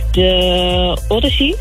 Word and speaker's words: The Odyssey: En zaterdag ben ik met dezelfde buurvrouw naar de bioscoop geweest naar de film, ik hoop dat ik het goed uitspreek, The Odyssey. – Ja, voor mij, voor zover ik weet, spreek je dat goed uit.